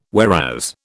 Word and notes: whereas: British English

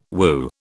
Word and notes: woo: British English

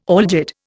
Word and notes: algid: Indian

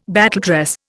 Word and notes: battledress: Indian

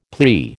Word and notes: plea: American English

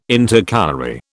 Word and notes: Intercalary: British English

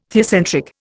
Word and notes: theocentric: Indian

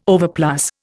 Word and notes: overplus: Indian

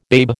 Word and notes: babe: American English

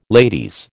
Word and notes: ladies: American English

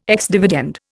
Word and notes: ex dividend: Indian

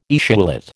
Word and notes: etiolate: American English

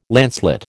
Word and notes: lancelet: American English

